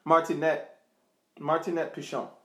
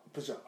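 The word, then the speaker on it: Marinette Pichon: She also credits, like, the reason why she fell in love with the French national team was due to Martinique Pacina. I want to say her name is Mark. I'll let you pronounce it again. – Martinette Pichon. – Pichon. Okay.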